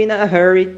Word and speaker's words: hurry: Hurry tem um som de R, porque ele começa com H. In a hurry.